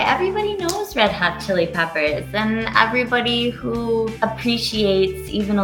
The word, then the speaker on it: Red Hot Chili Peppers: Today, we're talking about, and it's not pronounced Hedgehog. It's Red Hot Chili Peppers.